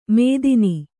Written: ಮೇಥಿನಿ

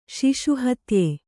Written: ಶಿಶುಹತ್ಯೆ

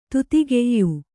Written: ದಾಧಿಕ್ಯ

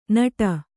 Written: ನಿಡಿ

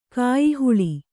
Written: ಕಾಯಿಹಾಲು

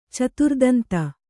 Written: ಚತುರ್ದಂತ

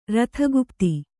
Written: ರಥಗುಪ್ತಿ

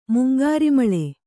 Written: ಮಂಗಾರೆಮುಳ್ಳು

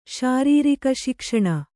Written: ಶಾರೀರಿಕಶಿಕ್ಷಣ

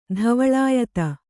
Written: ಧವಲಾಯತ

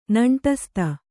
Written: ನೆಂಟಸ್ತ

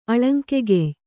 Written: ಅಳಂಕೆಗೆಯ್

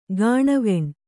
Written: ಗಾಣವೆಣ್

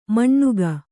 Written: ಮಣ್ಣುಗ

